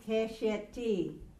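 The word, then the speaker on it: keshet (קשת): Keshet. – Now remember, it is segel-segel. When we do segel-segel, we pronounce it, or we say it, keshet, sefer, melek, deret, because it has the two segels.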